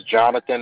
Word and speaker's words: Jonathan: My first name is Jonathan. It's spelled J-O-N-A-T-H-A-N. – And that was J-O-N-A-T-H-A-N, correct? – Yes, that is correct.